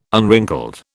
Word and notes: unwrinkled: British English

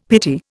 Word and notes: pity: Indian

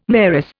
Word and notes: mayoress: Indian

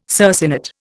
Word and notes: circinate: Indian

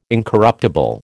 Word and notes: incorruptible: American English

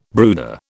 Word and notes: brooder: British English

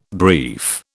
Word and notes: brief: British English

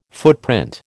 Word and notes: footprint: American English

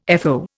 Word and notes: FO: Indian

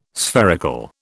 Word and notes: spherical: British English

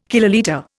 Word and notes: kilolitre: Indian

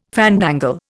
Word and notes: fandangle: Indian

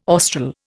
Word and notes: austral: Indian

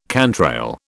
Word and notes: cantrail: British English

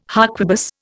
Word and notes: harquebus: Indian

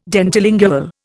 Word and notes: dentilingual: Indian

